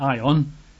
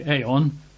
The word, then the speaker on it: aion: In English, that would be A-I-O-N. You might pronounce that Ion. I think biblical Greek classes tend to pronounce it as Ion, although I think those that try to get back to the original pronunciation say it's probably Aeon, A-I-O-N, or Aionios, which is just the adjective, A-I-O-N-I-O-S, Aionios.